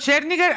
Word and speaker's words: Scherzinger: Nicole Scherzinger, ahí como se pronuncia en inglés, Robbie Williams.